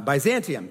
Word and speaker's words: Byzantium: Byzantinium? I'm not saying that right. Byzantium. There we go.